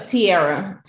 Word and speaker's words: Tierra: Tarianne Mack? – No, Tierra. Tierra Mack. – Oh, Tierra. Rhymes with Sierra.